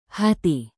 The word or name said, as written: hati